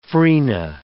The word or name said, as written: frena